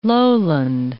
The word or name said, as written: lowland